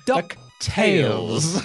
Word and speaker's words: DuckTales: I love the Games Master in this because he very firmly pronounces it as two separate words. It's DuckTales. – DuckTales.